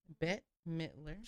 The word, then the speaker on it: Bette Midler: Bette Midler.